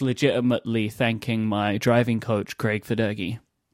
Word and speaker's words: Federighi: I didn't pronounce it right. It's Craig Federighi.